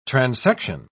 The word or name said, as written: transection